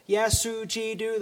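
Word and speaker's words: Yasu Ji Du (耶稣基督): Just to give you an example, in China, if you were to simply say to every single Chinese person 4 syllables, Yasu Ji Du, that's how you say Jesus Christ in Chinese.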